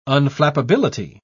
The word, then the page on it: unflappability: British English